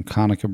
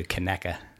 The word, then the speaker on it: Conecuh: Comes to us from Conecuh Brands. – I'm going to correct you too. Conecuh. – Conecuh. – Yeah. So you had the name right. – So what's the sausage called? Is it Conecuh sausage? – Yep.